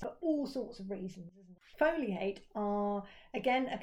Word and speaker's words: Foli8: And another one for your interest, Foliate. No, it's spelled F-O-L-I, figure eight.